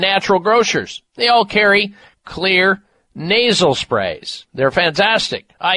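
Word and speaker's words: Xlear: Now, Clear is spelled with an X, X-L-E-A-R, Clear Max Nasal Spray.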